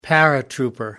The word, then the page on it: paratrooper: British English